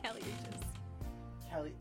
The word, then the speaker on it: Kali Uchis: That you said it right. – I said Kali Uchis. Kali Uchis.